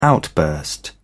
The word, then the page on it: outburst: British English